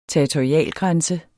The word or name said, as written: territorialgrænse